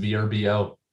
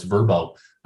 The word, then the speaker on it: Vrbo: The Verbo Citrus Bowl, no longer pronounced VRBO. Their people got in touch with me over the previous years and wanted the LA faithful to know it's Verbo.